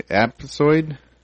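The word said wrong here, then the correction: aptosid: Oh, Aptisid.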